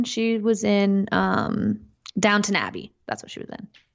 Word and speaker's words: Downton: No, there's no W. Mm. Well, there's one. – Well, British people don't pronounce the W, so it's Downtown Abbey. Okay, whatever. Never seen it. – No, I'm fucking with you. It's Downton Abbey.